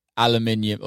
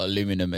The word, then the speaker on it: aluminium: But who the fuck buys a 7-foot aluminium or aluminum, as you Americans say, sculpture and puts it in their house? – So a successful real estate guy.